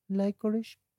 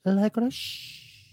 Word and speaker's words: licorice: Clararirá que sí, ¿Cómo se pronuncia? – Licorice Muy bien. – Ay, pobrecito, pensaba pero, pero te faltó el shh. Licorice eso, muy bien.